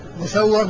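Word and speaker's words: مُسَوَّر: مسور ومسور، مسور بضمن الميم وفتح السين والواو المشددة، ومسور بالميم المكسورة والسين الساكنة والواو المفتوحة.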